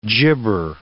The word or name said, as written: gibber